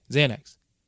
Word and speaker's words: Xanax: before it became Xanax. Xanax, did I say that right? Yeah, Xanax.